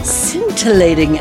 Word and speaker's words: scintillating: Welcome to another scintillating episode of Good Witch, Bad Witch. Scintillating has a silent C. Did you know? – Bring out your fingers.